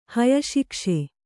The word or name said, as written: ಹಯಶಿಕ್ಷೆ